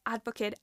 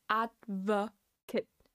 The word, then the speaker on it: advocate: And keep in mind that this word can be used as a noun and as a verb, but they're pronounced differently. If you want to use advocate as a noun, you would say advocate, advocate, advocate. The stress is in the first syllable, advocate.